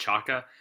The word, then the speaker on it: Chayka: And I think the, uh, I believe uh, his last name is pronounced Chayka, uh, has done a, a freaking incredible job with Arizona since he's taken over there.